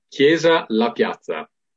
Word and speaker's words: Chiesa La Piazza: In Italian, it sounds like cheese pizza. how do you pronounce it? – It's Chiesa La Piazza.